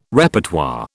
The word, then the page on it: Repertoire: British English